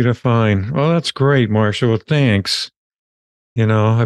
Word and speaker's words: Marcia: And Marcia, you spell it M-A-R-C-I-A. – C-I-A. Yep. Yeah. And I pronounce it Marcia, not yep. Marcia.